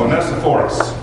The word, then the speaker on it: Onesiphorus: Onesiphorus. I'm sorry? Don't ask me to pronounce that. – Onesiphorus.